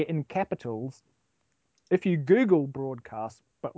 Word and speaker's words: Broadwcast: Well, it's pronounced broadcast or broadcast, as they say.